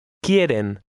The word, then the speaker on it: Quieren: Quieren. Quieren. – Notice that the Q-U combination sounds like a K.